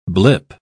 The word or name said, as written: blip